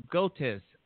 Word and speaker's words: Goetz: Author Bracha Goetz on the Show.